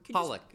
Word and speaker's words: Holoch: Did you say Holly? – Hollick is my last name, yes. – How do you spell it? – H-O-L-O-C-H.